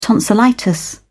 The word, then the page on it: tonsillitis: British English